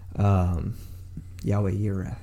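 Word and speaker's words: Yahweh Yireh (יהוה יִרְאֶה): It's like the Cajun way of saying they use probably David or Yahweh Yireh. That's how you say it in Hebrew.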